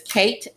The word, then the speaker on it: Kaiit: Her name is Kate, and it's spelled K-A-I-I-T, like short for Caitlin.